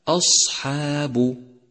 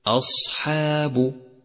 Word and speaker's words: أصحاب: ثالثا، اقرأ كلمة أصحاب، أصحاب، اقرأها بشكل صحيح مراعيا ترقيق الحروف وتفخيمها، فالهمزة مرققة دائما، والصاد مفخمة، والها صفة الصفير أيضا، والحاء مرققة، والألف تتبع الحاء في الترقيق، والباء مرققة، فيصير نطقها الصحيح هكذا أصحاب، أصحاب.